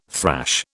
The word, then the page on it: thrash: British English